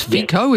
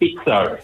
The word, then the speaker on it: Fico: I think it's FICO, is that right? – Well, it's pronounced FITZO. – FITZO, okay. – F-I-C-O.